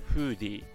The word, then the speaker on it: Foodie: ィの新しいフィルターについてご紹介しますさあいろんなカメラアプリありますけども皆さんはフーディというアプリご存知でしょうかえ綴りとしては FOODIE ということでフーディと読むんですけれども、まあ、フーディってカタカナで検索していただいてもすぐ、えー、アプリのストアで見つかると思います。